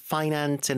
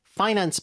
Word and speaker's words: finance: 자, 그 다음에 How important finance and investing are, 어디 어디에, to our society and future. 자, finance 발음이요. – Finance. – 앞에 주시고. Finance.